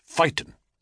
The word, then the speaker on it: Phaeton: It's usually pronounced to rhyme with Satan or Nathan, though you can, if you prefer, rhyme it with Titan or Python, as Phaeton.